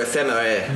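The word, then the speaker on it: Éphémère: Spell that for me. – Pomme or ephemera? – Both. – E-P-H-E-M-E-R-E. – Ephemera. – Okay. – Ephemera. The French really like their vowels. – Mm-hmm. And then the apple? – Pomme. P-O-M-M-E.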